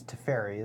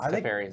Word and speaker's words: Teferi: I think it's Teferi, isn't it? – What? – Yeah. I think it's Teferi as well.